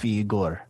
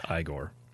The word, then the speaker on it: Igor: Soon enough, Igor. – Yeah. I don't know. I think it's pronounced Igor. – Is it Igor? – It's a young Frankenstein.